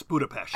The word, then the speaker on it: Budapest: Well, the way I've always pronounced it is Budapest, sort of like an SHT sound. But I will say that I'm not completely sure on that. – Well, you're correct. It's Budapest.